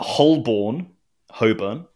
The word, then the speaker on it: Holborn: The Novelty Automation Museum is in Holborn, but it's spelt Holborn. That's another one. That'll catch you. – So no L. – That'll get you. – No L, no R.